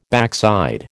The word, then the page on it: backside: American English